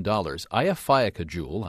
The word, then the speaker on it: Eyjafjallajökull: Eyjafjallajökull, I learned to pronounce it.